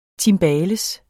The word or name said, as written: timbales